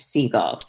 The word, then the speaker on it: Segal: I always say my last name's like Seagal, but it's pronounced Siegel, right?